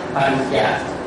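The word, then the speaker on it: pañca: Trang số hai Pan Cả, tiếng Pali đọc là Pan Cả, Pan Cả, Pan, cái N mà có dấu ngà đọc là nhờ, thành là Pan. Chữ C với chữ A đọc là Chà, Pan Chà.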